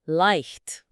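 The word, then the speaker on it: leicht: وكلمة سهل لايخت.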